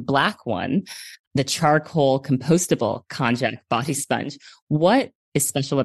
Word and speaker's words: Konjac: It's, I hope I'm pronouncing it correctly, the Konjac body sponge. Am I pronouncing that correctly? – Yeah, you are.